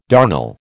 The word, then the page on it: darnel: American English